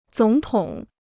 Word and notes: 总统: zǒng tǒng

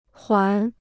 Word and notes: 环: huán

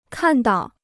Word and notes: 看到: kàn dào

